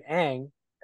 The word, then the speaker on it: Aang: Because that's how you pronounce his name, Aang. It's spelled A-A-double-A-N-G. So it's pronounced Aang in the show.